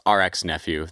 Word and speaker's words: RX Nephew: I suggest a lot of people go watch the No Jumper interviews, because those are long-form, in-depth interviews with, well, it's RX Nephew. The K is silent.